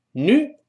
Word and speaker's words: new: Buy a new one。 Buy 就是买，buy 啊，连到一块的买一个 buy a new one。 New 这标准的美式发音。那么英式发音呢，往往要加一个 e，就发 u 的声音，就是那个 na 辅音跟 u。” 连在一块读成 new，它有个 e 这个声音，美音中 u 绝大多数情况下会变成 u 这个声音，就是把前面的 e 去掉，只剩下一个 u 这个声音，所以读成 new。